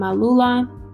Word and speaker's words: Alula: My name is Ariam Alula.